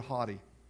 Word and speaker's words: hoti: And each of these realities are set off in the original language by the Greek word hoti, H-O-T-I, if you were to spell it in English, hoti.